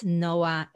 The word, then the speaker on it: Noa: So that's on social media and my website, Noa Ronen coaching. And Noah is without an age because that's an Israeli woman. The Israeli guy is Noah with an age, which in Hebrew we pronounce Noah.